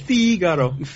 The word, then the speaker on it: Figaro: I believe it's pronounced Figaro. – Yeah, that's exactly. You nailed it.